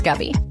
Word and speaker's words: Gabby: Yeah, it's spelled Gabby, but it's pronounced Gubby.